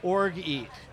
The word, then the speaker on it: orgeat: O-R-G-E-A-T. Orgeat. – Orgeat? – Orgeat. – Orgeat. Orgeat. – Lemon and bitters. – Yep. – Orgeat. – So that sounds interesting. – What do you think orgeat is?